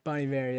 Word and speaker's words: Bon Iver: Is that how you say it? Bon Iver? – Bon Iver, yeah. – I did not know that. I thought it was Bon Iver.